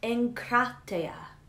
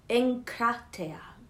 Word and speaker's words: enkrateia: This word is pronounced enkrateia, enkrateia.